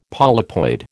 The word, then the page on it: polypoid: American English